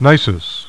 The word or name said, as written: Nisus